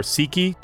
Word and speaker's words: Sikhi: It's with a short I, not Sikhi. A long I.